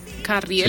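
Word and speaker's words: Ca7riel: Artistas como, no tengo ni idea cómo se menciona esto, pero se escribe de la siguiente manera: CA7 Triel, o sea, leyéndolo rápidamente. – Carriel, señora.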